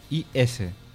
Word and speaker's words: Ys: Recordad que hay el prefijo IS.